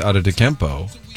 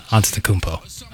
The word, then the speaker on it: Antetokounmpo: And you got props yesterday for saying Giannis Antetokounmpo during your updates. – Yes, Antetokounmpo. – Well, I got praise for pronouncing it correctly, and you just pronounced it incorrectly.